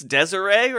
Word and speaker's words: Deseret: I believe it's Deseret, right? I have that is the Utah pronunciation.